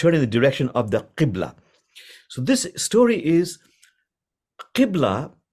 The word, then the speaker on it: Qibla: Q-I-B-L-A, Qibla.